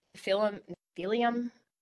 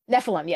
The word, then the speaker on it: Nephilim: Well, have you ever heard of the Philium? – Oh, the Nephilim? – Nephilim.